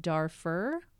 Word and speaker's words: Darfur: Officials in an area of Darfur, is that how you say it? – Close enough.